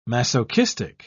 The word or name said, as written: masochistic